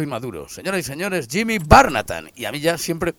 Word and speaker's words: Barnatán: Entonces, yo es que ayer estaba ensayando, digo, le decía a mi santa, digo, recuérdame que se pronuncia Barnatán, digo, porque a ver, luego los artistas son muy pejigueros para estas cosas y me...